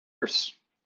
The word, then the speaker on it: Peirce: Peirce. Happens to be pronounced Peirce.